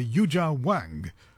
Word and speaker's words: Yuja Wang: and the recording we heard was by Yuja Wang, I think is the more correct pronunciation, and she herself is also a virtuoso player.